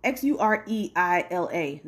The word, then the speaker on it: Xureila: Let me make sure I said that right. Yeah, X U R X U R E I L A Xureila.